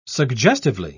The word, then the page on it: suggestively: British English